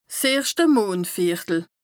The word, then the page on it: 's erschte Mondvìertel: Bas-Rhin; Strasbourg